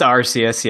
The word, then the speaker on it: Arceus: Is it Arceus? – It's Arceus, yes. You're correct.